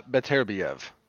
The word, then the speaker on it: Beterbiev: I said Artur Beterbiev. That's how you pronounce it, no? – Beterbiev. – Beterbiev. Better? – Beterbiev. – Right. Beterbiev? – Yeah.